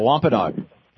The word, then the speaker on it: Wampanoag: Yeah, the Wampanoag.